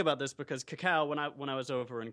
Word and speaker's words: Kakao: Korean messaging app, Kakao.